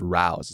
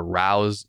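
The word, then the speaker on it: Rao's: And she told me to get something called Rouse. It's spelled R-A-O. So we said the name Rouse multiple times over the phone.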